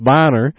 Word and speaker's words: Boehner: Republican House Speaker Boehner, I always call him Boehner, I don't know, I guess it's pronounced Bonner, has provided it yet again.